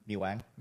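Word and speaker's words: Mì Quảng: Is it M-I-Q-U-A-N-G? – Yeah, I mean, everybody, it's different accents. – Do you not supposed to pronounce the Q? – No, I do not.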